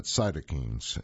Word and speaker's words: cytokines: And I should say that cytokines is the correct pronunciation, which aid the body's cellular response to fighting a foreign pathogen or tumor.